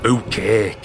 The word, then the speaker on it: Bucket: It's pronounced bouquet, Captain. You know, it's like French.